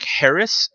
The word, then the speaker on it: Haracz: So on any social media, my name, you know, just search my name, Mike Harris, and it's spelled HARACZ.